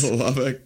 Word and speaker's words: Lubbock: I'm in lovely Lubbock. Is that how you pronounce it? – Yes. – Lubbock, Texas? – Yes.